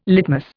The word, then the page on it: litmus: Indian